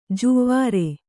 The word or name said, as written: ಜುವ್ವಾರೆ